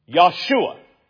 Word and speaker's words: Yahshua: His name was Yahshua. Yahshua. By the way, that's the Hebrew Aramaic name for what we pronounce as Joshua.